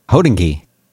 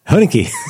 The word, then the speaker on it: Hodinkee: Hodinkee. – Hodinkee. – That's how I say it. Instead of the emphasis on dinky, I like Hodinkee.